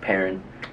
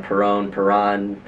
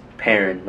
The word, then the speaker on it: Perrin: So I'm just gonna be myself, stick to my moniker of just Nelson Perrin. For those who don't know, it's pronounced Perrin. A lot of people pronounce it wrong. Perron. It's Perrin. Like if you put a pear in the bag or like some, I don't know.